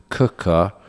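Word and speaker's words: Quooker: Yeah, this podcast is sponsored by Cooker, spelt Quicker, pronounced Cooker.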